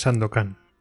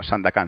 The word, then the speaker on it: Sandakan: Perdona que haga la broma, no es Sandokan. – No, no, Sandakan, Sandakan. – Pero se escribe, o sea, todo con, con vocales. – Es como Sandakan Sandokan, pero... – Pero todo es en as. – Pero con as todo.